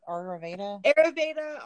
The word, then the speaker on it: Ayurveda: Ayurveda. Is that how you say it? Ayurveda? – Ayurveda. Ayurveda. It's obviously not English, so.